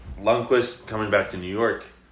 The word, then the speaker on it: Lundqvist: Lundqvist.